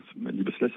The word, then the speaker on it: Liebeslied: Et ce Liebeslied, Liebeslied, ça veut dire chagrin d'amour. – Ah, j'avais mal prononcé. Alors, Liebeslied.